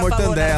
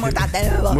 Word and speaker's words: mortadela: Mortadela, eu sempre falei errado, sabia? – Ah, muita gente já mortandela. Falou na vida mortandela. Ah, 100 gramas de mortadela.